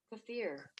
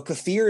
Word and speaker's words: kefir: Kefir. – Kefir. So kefir is spelled K-E-F-I-R. Pronounced kefir.